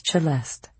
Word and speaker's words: Celeste: Her name was Celeste, pronounced with the sharp ch, as in cello.